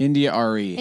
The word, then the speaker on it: India Arie: India Arie.